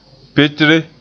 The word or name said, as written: petre